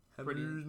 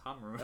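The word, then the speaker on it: Ħamrun: Hamrun. – That's how you say it. I googled how to pronounce Hamrun.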